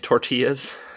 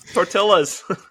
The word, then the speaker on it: tortillas: Today I read Taylor Swift makes music for white ladies who pronounce the L in tortillas. Or L's, I guess. Tortillas.